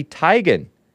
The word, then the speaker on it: Teigen: It's Chrissy Teigen, I think. Not Teigen, not I wanted to call it Teigen because it sounds E before I, right? Chrissy Teigen, but it's actually pronounced Chrissy Teigen, according to her mother.